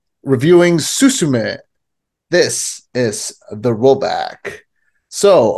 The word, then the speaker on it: Suzume: No, Suzume. – I was pronouncing it Suzumi. – Oh, yeah, you were quite wrong. Okay.